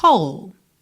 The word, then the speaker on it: whole: Whole. W-H-O-L-E. E a pronúncia é whole, ok?